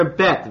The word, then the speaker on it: Bet: This is the second letter, Bet, it's pronounced like a B.